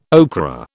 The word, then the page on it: okra: British English